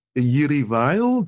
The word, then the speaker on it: Jiří Weil: Jiří Weil, do I pronounce that name correctly? – That's fine.